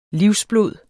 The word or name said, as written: livsblod